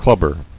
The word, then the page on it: Clubber: club·ber